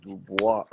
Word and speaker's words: Dubois: As I said, Dubois. French pronunciation. It is a French name.